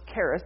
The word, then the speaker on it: charis: It's pronounced charis. If you hear anybody say charis, you say Merry Christmas to you. I believe in Jesus Christ. It is charis.